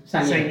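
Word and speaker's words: saignée: Is that how you pronounce it? I've always said Seigneur. Seigneur, if you're going to be really French.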